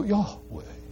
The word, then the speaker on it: Yahweh: And so Yahweh seems to be the right way to say it. That's a 20th century conclusion that's resounding among the scholars, though not entirely certain.